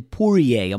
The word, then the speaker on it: Poirier: Conor McGregor, Dustin Poirier. Am I saying that right? Poirier? – You are.